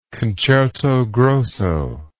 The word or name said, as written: concerto grosso